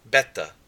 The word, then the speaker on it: beta: So our pronunciation, alpha, ah, father, alpha, beta, ba, boy, beta,